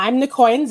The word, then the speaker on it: Niccoyan: I would like to give a warm welcome to Niccoyan. No, that's not how I pronounced it. Crap. I'll let you introduce yourself because I'm horrible with names. Take it away. – So I'm Niccoyan Zheng, and I'm a Canadian author, and the majority of my books will be set in Canada.